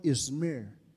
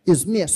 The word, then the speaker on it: Izmir: Now, today, as I'm speaking, when you look at SMYRNA, it's now called Izmir. That's how they pronounce it in Turkey.